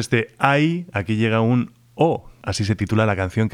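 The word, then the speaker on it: eau: O, que es una letra así pronunciada, pero que se escribe en tres letras, E, A, U, y como muchos habréis adivinado, quiere decir agua en francés.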